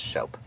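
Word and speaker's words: scop: But the Anglo-Saxon word for poet was shope, which looks like scope, but it's pronounced shope.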